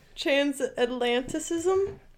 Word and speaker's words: Transatlanticism: What is the one's Transatlanticism. Transatlanticism? – Yes, there we go. – Oh, I said it right? – Yes.